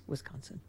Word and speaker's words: Wisconsin: Wisconsin. – Yes, Wisconsin.